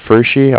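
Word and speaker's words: Pferschy: Yeah, my last name is Fershey.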